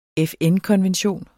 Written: FN-konvention

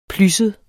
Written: plysset

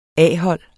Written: A-hold

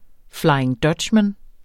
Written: flying dutchman

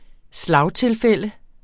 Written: slagtilfælde

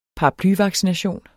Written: paraplyvaccination

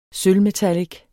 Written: sølvmetallic

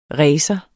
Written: racer

